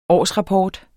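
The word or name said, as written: årsrapport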